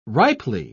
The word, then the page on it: ripely: British English